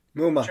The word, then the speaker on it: Muma: Is it pronounced Muma? Muma.